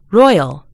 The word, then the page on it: royal: British English